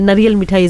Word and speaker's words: नारियल मिठाई: But yeah, नारियल मिठाई जो कहते हैं, नारियल मिठाई is how we say it, how we pronounce it.